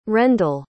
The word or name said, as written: rendall